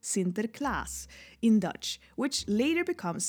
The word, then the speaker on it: Sinterklaas: Sinterklaas, yeah.